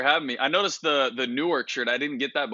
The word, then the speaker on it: Newark: I'm born and raised in Newark, and this is how it's supposed to be pronounced, N-O-R-K.